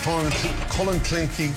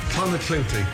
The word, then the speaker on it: Clonakilty: Clonakilty. – Clonakilty. – Clonakilty, thank you.